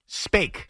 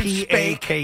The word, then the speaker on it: Spake: Shannon what? – Spake. – Shannon Spake. – I'll have the throw in there so you can all know how it's pronounced.